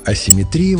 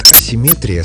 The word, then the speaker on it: асимметрия: А асимметрия вошла позже, уже как термин научного языка, ближе к греческому оригиналу, где ударение было иным. Вариант асимметрия словарями не поддерживается и считается ошибочным.